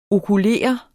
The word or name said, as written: okulere